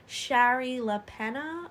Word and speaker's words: Shari Lapena: Shari LaPena, I want to say pronounce it.